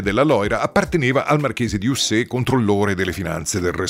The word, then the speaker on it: Ussé: Cercate le immagini di Castello di Ussé con l'accento sulla E e vedrete che meraviglia è,